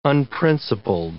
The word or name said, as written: unprincipled